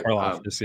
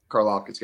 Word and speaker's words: Karlaftis: I always mispronounce his name. – Karlaftis, um, yeah, yeah. – Karlaftis, yeah.